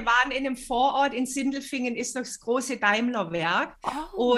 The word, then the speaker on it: Sindelfingen: In Sindelfingen, wenn ich das jetzt richtig ausspreche.